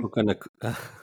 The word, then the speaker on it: Puka Nacua: Puka Nakua, is that how you say his name? – Puka Nakua.